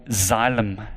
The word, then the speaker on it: Xylem: I work for an American company that's called Xylem, and you spell it X-Y-L-E-M, Xylem.